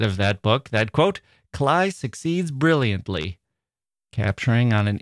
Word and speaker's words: Klay: but I think I can handle K-L-A-Y as Clay. And he said, well, actually, it's Cly.